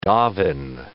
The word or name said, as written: daven